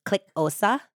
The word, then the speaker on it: Xhosa: So it's spelled X H O S A, but I did read that the X is pronounced like a click, so it's like click Osa. Osa.